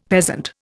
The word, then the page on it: peasant: Indian